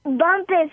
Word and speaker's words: Bumpus: Bumpus. B-U-M-P-U-S.